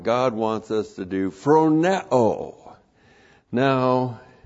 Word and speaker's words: phroneo: Phronema comes from the verb phroneo. Comes from the verb phroneo. P-H-R-O-N-E, long O.